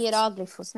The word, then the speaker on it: hieróglifos: Hieróglifos, né? Isso, pode ser. Talvez a sílaba tônica seja outra.